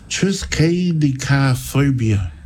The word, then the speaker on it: triskaidekaphobia: What's that trichophobia? – Yeah, it's, here we go. – How do you spell it? – Trisk. So it's T-R-I-S-K. – Yeah. – A-I-D-E-K-A. Phobia. – Oh. – Triskaidekaphobia.